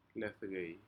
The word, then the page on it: ledcei: Aneityum